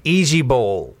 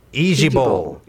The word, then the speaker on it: IJBOL: Instead of saying each letter, you're supposed to say easy bowl. – Oh. – Easy bowl.